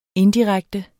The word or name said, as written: indirekte